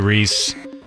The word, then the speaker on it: Rhys: It's got Matthew Rhys. Is that how you say it? – Yeah, Matthew Rhys.